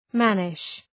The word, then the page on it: mannish: ‘mænıʃ